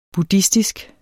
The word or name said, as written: buddhistisk